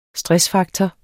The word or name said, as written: stressfaktor